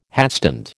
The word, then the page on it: hatstand: American English